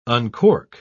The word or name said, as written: uncork